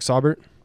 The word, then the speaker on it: Saubert: My guy, Saubert, right? Did I say that right? – Yeah, Eric Saubert. – Saubert. That's what I said.